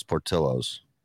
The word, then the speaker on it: Portillo's: No, it's Portillo's.